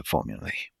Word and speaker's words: formulae: Let me double check the pronunciation of the plural of formula. Yeah, it's pronounced formulae.